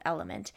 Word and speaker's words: LMNT: And I want you guys to know about a electrolyte that I've been using called Element. It is spelled LMNT.